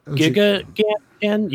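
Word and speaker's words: Elgiganten: El Giganten. Yeah, I think you said it right.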